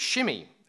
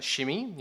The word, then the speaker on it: Shimei: And then we meet Shimei, who I'm going to pronounce Shimei. You can pronounce him Shimei if you like, but I've had a look at the Hebrew, and it looks like it might actually be pronounced Shimei, which is quite fun.